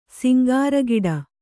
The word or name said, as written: ಸಿಂಗಾರಗಿಡ